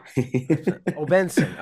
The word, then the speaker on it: Obenson: Obenson.